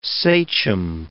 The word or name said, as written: sachem